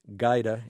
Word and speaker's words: Gajda: If you're going to look for the book, her last name is spelled G-A-J-D-A, pronounced Gaida.